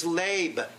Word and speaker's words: leb: The Greek, the Hebrew word is leb, L-E-B, long E, leb.